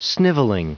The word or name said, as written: snivelling